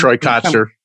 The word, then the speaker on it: Troy Kotsur: Is that how we say his name? Yeah, Troy Kotsur.